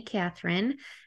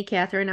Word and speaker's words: Cathryn: The last name is spelled CATHRYN. And I'm also big on Instagram. I love Instagram. It's at Ms. Annie Catherine. I'm on Twitter at Ms. Annie Catherine.